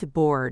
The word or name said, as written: to board